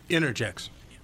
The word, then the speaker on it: Innergex: Canada's uh, Inter, Innergex is how oh, I want to inter, say this. Innergex. – Inter, inter, How do you pronounce that? Innergex. It is Innergex.